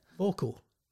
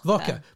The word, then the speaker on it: Voca: Vocal. – Voca. – Voca. – Voca. Why is it not... – V-O-C-A. – Yeah. Why is it not vocal then? Voca.